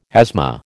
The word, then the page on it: asthma: American English